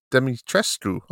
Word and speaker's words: Dimitrescu: How do you say her name? Is it Lady Dimitrescu? – Dimitrescu. Okay. Lady Dimitrescu.